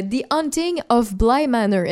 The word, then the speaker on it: The Hunting of Bly Manor: Euh, The Hunting of Bly Manor.